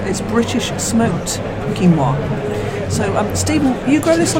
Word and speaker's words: quinoa: I know a lot of people call it quinoa. That's what it looks like. We actually pronounce it quinoa, and I'm with Christy, our producer here, and we've stopped off, and we're talking to Stephen Jones.